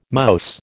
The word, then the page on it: mouse: American English